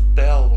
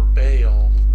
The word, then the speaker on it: Baal: A main one for them was Bel or Baal. Different pronunciations from different time periods.